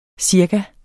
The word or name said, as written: cirka